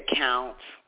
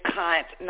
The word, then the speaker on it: cunt: You said count. It's cunt, not count.